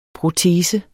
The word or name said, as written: PROTESEN